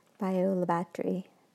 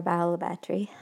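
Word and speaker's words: Bayou La Batre: Bayou-la-Batrie, or Bayou-la-Batrie, however you want to pronounce it, but both are accepted as correct in this area. So it's spelled three parts, B-A-Y-O-U, separate L-A, and then B-A-T-R-E.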